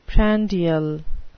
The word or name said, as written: prandial